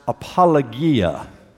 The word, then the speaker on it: apologia: The word translated defense or answer comes from a Greek word pronounced apologia.